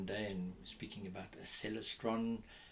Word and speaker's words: Celestron: And they said to him, no, our name is Celestron. – Right, right. – So I've always remembered and called it Celestron. – Because when you say it like that, you can see why. It's yes, you Celestron. Can see yes, why. Yeah. Exactly. So Celestron. So maybe people will learn now to say Celestron.